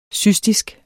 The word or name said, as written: cystisk